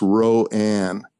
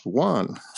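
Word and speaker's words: Rouen: And I kept thinking that the town was pronounced Ro-Ann. And so I would, huh? – You're not alone. – No. Okay. Yeah. And so in fact, it's pronounced Juan.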